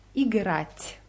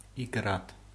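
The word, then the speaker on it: Igrat (играть): Spielen heißt Igrat. Igrat. Ich buchstabiere es. I-G-R-A-T. R-A-T Märchkiznak. Märchkiznak ist das Weichheitszeichen. Also Igrat. – Igrat. – Genau. Und Fußball spielen heißt Igrat v Football.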